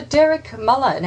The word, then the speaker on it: Muller: I'm talking to Dr. Derek Muller now.